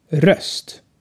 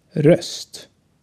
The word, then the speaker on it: röst: Dann haben wir denselben Laut, aber kürzer. Röst. Röst. Der Laut kommt im Deutschen Hölle vor.